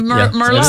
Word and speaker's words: Merlot: Yeah, remember Merlot is, and that T is silent on the end of Merlot.